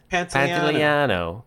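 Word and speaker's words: Pantoliano: And then Joe Pants decides Joe Papagliano. Is that how you say it? – Pantaliano is in this movie.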